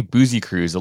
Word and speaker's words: Busekrus: It's actually Boozy Cruise, a little bit more Booze Cruise esque.